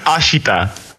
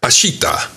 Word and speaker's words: Ashita: Ashita. – Ashita.